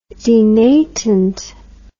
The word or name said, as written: denatant